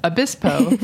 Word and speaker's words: Obispo: Our next guest is the food writer, photographer, and self-publisher of the San Luis Obispo, did I say that right? – Obispo Farmer's Market Cookbook, simple seasonal recipes and short stories from the central coast of California.